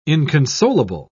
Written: inconsolable

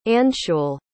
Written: anshul

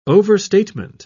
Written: overstatement

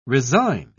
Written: resign

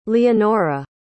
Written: leonora

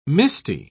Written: misty

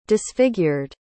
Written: disfigured

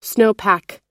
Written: snowpack

